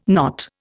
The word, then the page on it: not: Indian